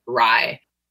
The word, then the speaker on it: RIE: So RIE are the letters and we pronounce it RIE as in rye bread.